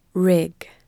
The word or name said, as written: rig